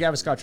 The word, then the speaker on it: Gavaskar: Bordy Gavaskar Trophy.